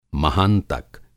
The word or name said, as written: महांतक